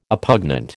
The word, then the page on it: oppugnant: American English